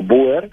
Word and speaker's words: Boer: Realmente la pronunciación original no sería Boer, aunque siempre decimos Boer, sino Boer, y quiere decir literalmente granjero, porque ellos se consideraban a sí mismos granjeros.